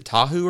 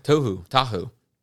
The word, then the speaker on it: Tohu: Is it Tahu or is it Tohu? – Tohu.